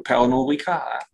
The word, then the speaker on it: Pernod Ricard: Und da gab es erst einmal sehr, sehr gute Zahlen von Pernod Ricard. Habe ich es richtig ausgesprochen? – Ich glaube schon, ja. Das wird Französisch, ein Franzose wird wahrscheinlich Französisch aussprechen. Pernod Ricard.